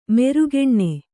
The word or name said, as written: ಮೆರುಗೆಣ್ಣೆ